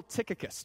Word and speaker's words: Tychicus: Well, one of those is the honorable Tychicus. Did I pronounce that right, Dave? All right, Dave's good with it.